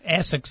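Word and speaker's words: Aşık: I think it's Asik. That's how it sounds phonetically, but yeah.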